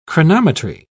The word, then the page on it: chronometry: British English